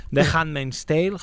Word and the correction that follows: Handmaid's Tale: no ho he pronunciat bé. Handmaid's Tale.